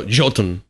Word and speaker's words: Jotun: But my winner would actually be a little indie game called, I'm going to mispronounce it, Jotun or Jotun? – Oh, Jotun.